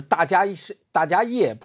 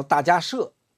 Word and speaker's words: 大迦叶: 叫迦摄佛，就是大迦叶，不叫大迦叶，他正确的发音叫大迦摄。